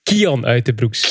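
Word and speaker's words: Kian Uijtdebroeks: Eerst en vooral, je moet zijn naam juist uitspreken. Ah, ja, als je hebt nu Remco Evenepoel noemt, hij heet Kian Uiterbroeks.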